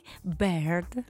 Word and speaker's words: Baird: Quindi 26 gennaio 1926, come 100 anni fa, ad opera di questo scozzese, che mi piace come lo pronunci, Baird. – Sì, si chiama così, B-I-R-D, John Logie Baird.